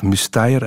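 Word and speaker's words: Müstair: Ja, dat, ken ik niet persoonlijk, maar om er eentje te noemen dat ik heel mooi vind, eh, in Muster, of Musteier uitgesproken, in Grabunde, Zuid-Zwitserland, is er zo'n heel mooi kapelletje met prachtige muurschilderingen uit de Carolingische tijd.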